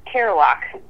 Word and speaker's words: Cearlock: How do you pronounce your last name? – Cearlock.